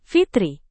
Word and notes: fitri: indonesian

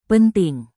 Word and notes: penting: indonesian

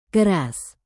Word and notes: keras: indonesian

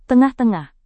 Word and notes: tengah-tengah: indonesian

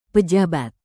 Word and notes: pejabat: Indonesian

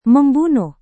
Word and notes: membunuh: Indonesian

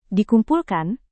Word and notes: dikumpulkan: Indonesian

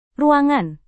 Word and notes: ruangan: indonesian

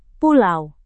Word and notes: pulau: Indonesian

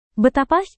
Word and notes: betapa: indonesian

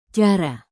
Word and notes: cara: indonesian

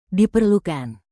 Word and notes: diperlukan: indonesian

